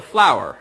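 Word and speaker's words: FLWOR: It's a flower expression.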